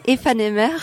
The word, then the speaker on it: Aephanemer: Tu l'avais bien prononcé au début, ne le perds pas, c'est FNMR.